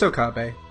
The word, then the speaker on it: Okabe: Yeah, it's Okabe.